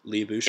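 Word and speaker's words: Lyubushkin: Labushkin? – Yep.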